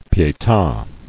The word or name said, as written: Pieta